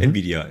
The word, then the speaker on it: Nvidia: wie wird das ausgesprochen? Nvidia? – Mhm. Nvidia, ja.